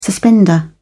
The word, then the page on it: suspender: British English